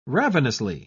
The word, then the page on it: ravenously: British English